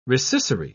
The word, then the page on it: rescissory: British English